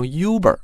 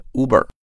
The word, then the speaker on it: Uber: 这里面我们又发现了啊，Uber Rich， Uber 又是一个前缀，表示超级的。哎，我们可能知道它是一个打车的软件啊，叫做优步。不过很多人因为这个中文的翻译版本啊，习惯的把这个词念错了，念成 Uber 啊。这个词不管是英音、美音都念 Uber。